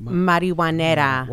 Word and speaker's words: Marijuanera: It's Marijuanera.